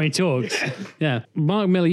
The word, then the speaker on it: Millar: Why do people say Mark Millar all of a sudden? – Americans do, because they're fucking idiots. – Do they see the A and go, well, it must be Millar? – Yeah, it can't possibly be pronounced the same way as Millar. – He's Scottish, it's Millar.